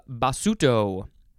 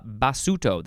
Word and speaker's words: Basuto: Now we have basuto. I think it's that's how it's pronounced. It is with a capital B A S U T O.